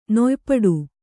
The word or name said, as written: ನೊಯ್ಪಡು